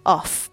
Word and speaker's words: of: 这边的 of 跟刚才那个 off off 不太一样，这个是 of of of 这个。 f 的发音虽然在这边是 f，但是呢，它的发音是 v 这个声音。